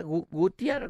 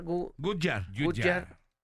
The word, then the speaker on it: Goodyear: Este es ¿Cómo se pronuncia? Gutiar, Goodyear.